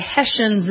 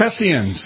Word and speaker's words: Hessians: All right, and just to continue on, by the way, Hessians is the proper pronunciation of those German mercenaries. – Hessians.